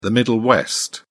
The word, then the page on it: middle west: British English